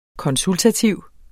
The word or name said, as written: konsultativ